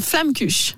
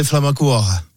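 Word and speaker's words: Flammekueche: Alors ça dépend si vous êtes barinois ou orinois, mais moi dans le Haut-Rhin, on disait flammacour.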